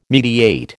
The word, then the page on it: mediate: American English